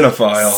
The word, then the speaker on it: cinephile: Cinephile.